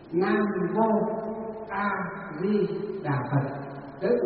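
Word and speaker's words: vô (無): Cái chữ mô á, chữ mô nó nguyên chữ Hán nó đọc là chữ vô. Chữ Hán đọc là chữ vô chứ không phải là chữ mô, mà là chữ vô.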